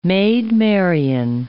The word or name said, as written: Maid Marian